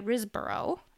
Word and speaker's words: Riseborough: I completely butchered his name there, but it stars Nicolas Cage and Andrea Riseborough, I think is how you say her last name.